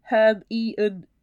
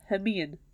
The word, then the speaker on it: Hermione: Bit like Hermione and Harry Potter, pronounced it Herm-e-un for years. I can't even say that. Hermione.